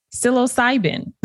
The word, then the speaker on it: psilocybin: Psilocybin.